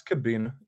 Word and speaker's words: Kubena: We're saying that right, by the way. Are we? – That's Kubena. Yeah. – Kubena. All right. Because it could go a couple ways. So I want to make sure right.